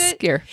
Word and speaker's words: skyr: The thick, like, is it skyr? – Yes. – How you pronounce it? – It is skyr. Yep.